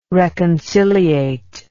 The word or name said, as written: reconciliate